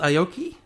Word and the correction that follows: Aiko: Have I pronounced it right? – No, Aiko.